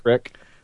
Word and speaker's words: Creek: He saw the one with Dawson Creek, you know, Mr. Creek. Mr. Dawson Creek. – It's pronounced Crick.